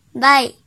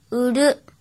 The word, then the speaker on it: 売: 和小一一起学日语第一百一十二课今日汉字买卖的卖，音读的时候读作 buy buy buy，比如店铺 buy 店 buy 店 buy 店，写成日语汉字是卖家店 buy 店。训读的时候，读作乌鲁乌鲁乌鲁，记住，u 是送假名。